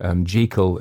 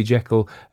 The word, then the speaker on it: Jekyll: Jekyll is how it was pronounced. I know it's mainly, it looks like it should be Jekyll, and it's been pronounced Jekyll because of that, and some people in my Jekyll will correct me, but they're wrong, and they're wrong about Wodehouse as well.